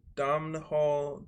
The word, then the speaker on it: Domhnall: Uh, do you know who Domino Gleason is? I don't know if that's how you pronounce it. Domhnall Domhnall Gleeson. – Donald Gleeson. – No, he Domhnall.